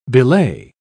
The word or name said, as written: belay